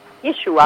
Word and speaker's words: Yeshua: Yeshua o Yeshua? – Yeshua. – Yeshua, ok. – Ci hanno detto, abbiamo chiesto proprio la pronuncia. – Ok. – Abbiamo cercato di, insomma, seguire bene i consigli di chi conosceva l'ebraico.